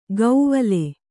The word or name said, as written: ಗೌವಲೆ